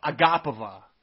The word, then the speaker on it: Agapova: Agapova. It's actually, it's actually Aga- Agapova.